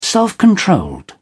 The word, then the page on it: self-controlled: British English